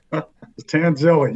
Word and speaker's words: Tanzilli: How do I pronounce that right? I'm so English. – Tanzilli, you're getting there.